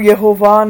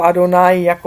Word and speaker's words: Adonai: Tetragram je tetragram Yud-Heh-Vav-Heh, ale já ho nemám tedy vyslovovat jako někteří, jako Jehova nebo Jahve nebo tak, ale mám to vyslovovat jako Adonai, jako pán, jo.